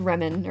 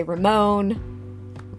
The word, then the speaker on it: Remon: It's spelled R-E-M-O-N. It looks like Remon, or that's how my phone pronounces it. It's pronounced kind of Frenchy, Remon. It's always fun to hear people try to pronounce it. I've heard people say Ramon, Raymond.